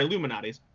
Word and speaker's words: Malnati's: Almanati's or Almanados. Uh, I forget how you pronounce that. Anyway, but or sorry, Lou Malnati's.